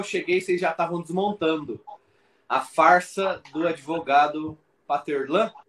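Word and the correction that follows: Pathelin: É assim que Patelan. fala? Que pronuncia? Patelan. – Patelan, ah, então.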